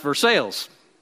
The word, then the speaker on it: Versailles: I have a friend whose mother lives in Kentucky, in a town that's named after the French Versailles. It's spelled the same way, but in Kentucky it's pronounced Versailles.